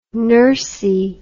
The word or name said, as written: nursey